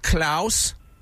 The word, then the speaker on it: Klaus: Yes, Klaus. – How do you spell that? – It's K-L-A-U-S.